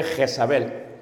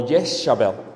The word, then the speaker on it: Jezabel: En la Biblia se va a pronunciar en Tiatira el nombre Jezabel o Yeshabel.